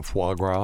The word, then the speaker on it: foie gras: I always mispronounce it. Frog wah. – Yeah, foie gras.